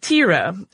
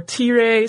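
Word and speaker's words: Thyra: And Gorm married a woman named Tira, or Tire, sometimes you'll hear it pronounced.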